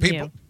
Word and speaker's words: Mu: Greek pronunciation is Mu. – People have been associating it with cows when they should be associating it with cats.